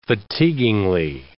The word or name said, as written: fatiguingly